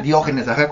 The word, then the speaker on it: Diógenes: Diógenes, con acento en la O.